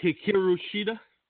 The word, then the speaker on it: Hikaru Shida: Hikaru Shida.